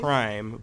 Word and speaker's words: Prhyme: It's called Prime, but it's spelled P R H Y M E.